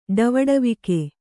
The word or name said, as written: ಡವಡವಿಕೆ